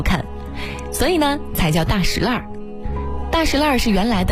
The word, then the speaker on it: 大栅栏: 比如说前门外的大石烂是极为著名的闹市，俗称大石烂。那为什么要叫大石烂呢，而不叫大栅栏？其实啊，经过考究，它的读音呢属于地名的特殊读音，历史悠久的大石烂读法呢属于保留古音的读法。